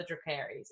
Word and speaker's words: Dracarys: And I literally called this one Dracarys without the Dracarys. Is that how they pronounce it? – Dracarys. Yeah.